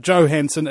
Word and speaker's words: Johansson: Everybody talks about Scarlett Johansson. It's Johansson, bro. All right. It's like yo. You know, you ever seen that scene in Rush Hour? That's how it's pronounced.